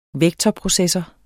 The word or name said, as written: vektorprocessor